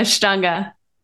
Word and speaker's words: Ashtanga: She is also a certified Ashtanga yoga teacher. Is that the correct way to say it? – Yeah, Ashtanga.